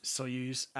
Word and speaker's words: Soyuz: Soyuz MS-09.